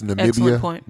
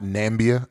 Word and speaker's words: Namibia: folks, that's Namibia, not Nambia, as our current sitting president would like to pronounce it. That is Namibia.